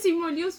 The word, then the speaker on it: Simu Liu: ¿Qué dice Simu Liu? Suena, se murió, pero se murió.